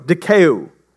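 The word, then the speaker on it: Dikaiō (δικαιόω): The Greek word for this phrase, justified by works, is dikaiō. Dikaiō. There we go. I said it right that time.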